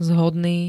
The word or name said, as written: zhodný